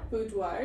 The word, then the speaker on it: Boudoir: Boudoir, yeah.